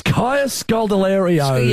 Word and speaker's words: Kaya Scodelario: It's Kaya Scodelario.